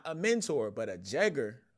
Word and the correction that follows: Jegna: right? – Jegna, yes, sir. – How you say it? Jegna. Jegna, yeah. Is